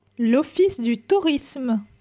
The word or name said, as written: L'office du tourisme